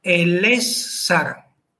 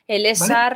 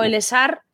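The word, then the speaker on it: Elessar: Por ejemplo, el es sar. – El es sar, ¿vale? Yo creo que es una palabra que el 75% de la población tolkiendili, y estoy siendo muy generosa, pronuncia mal. Yo he oído yo he escuchado el esar pues desde que tengo uso de razón.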